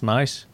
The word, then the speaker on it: Nice: Is it pronounced Nice? – It's Nice.